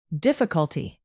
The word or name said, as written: difficulty